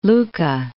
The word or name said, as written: Lucca